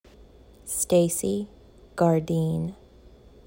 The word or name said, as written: Stacey Gardin